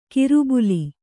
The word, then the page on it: ಕಿರುಬುಲಿ: kiru buli